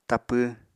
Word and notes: tapw: Apatani

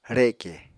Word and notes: reke: Apatani

